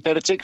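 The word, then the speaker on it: Terzić: Bueno, es Edin Terzic,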